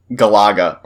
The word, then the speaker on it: Galaga: It is Galaga, or Galaga, whichever way you want to pronounce it. – It's Galaga. It's not Galaga. – It's Galaga. Stroke. – I've heard people say it both ways, and I don't know which one's right.